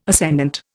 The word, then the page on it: ascendant: Indian